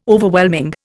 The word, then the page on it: Overwhelming: Indian